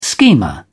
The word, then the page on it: schemer: British English